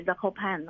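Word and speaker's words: Zakopane: pan，那后面的 ne 有些发音，有些不发音，然后我们一般是称它为 Zakopan 哦。那这个 Zakopan 它这个城市被称为波兰的冬天首都，为什么？因为波兰我上一期有讲过，它都是平原嘛，没什么山。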